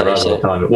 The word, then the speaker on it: Ras Al Khaimah: Is it Ras Al Khaimah? – Yeah, Ras Al Khaimah.